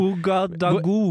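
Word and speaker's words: Ouagadougou: Ouagadugugu. Nei, det var én G for mye.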